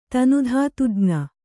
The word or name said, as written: ತನುಧಾತುಜ್ಞ